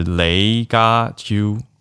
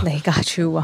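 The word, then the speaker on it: 李家超: 然后另外有听友补充关于粤语发音是雷嘎秋，雷嘎秋是这样吗？雷嘎秋啊，对，谢谢大家用用文字。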